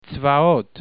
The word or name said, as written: tsvaot